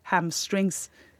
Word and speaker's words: hamstrings: Eh, og dette her antar jeg er, og du er ikke aleine om det, å kalle dette for hamstring. – Nei. Eh, det er en leseuttale, og den brukes så spesifikt om akkurat akkurat denne muskelgruppa. Det er, det er, litt snodig, men, men jeg forstår også at, at, folk tyr til den eh, uttalen. Men det er altså hamstrings, skinkestrenger.